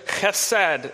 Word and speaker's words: chesed: But in order to look super smart and theological, I'll throw a Hebrew term at us this morning, and the term is chesed. That's the only time I'll do the ch, because I don't want to be distracting, but that's how it's pronounced, the chesed love of God.